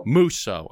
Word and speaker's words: Musso: Musso.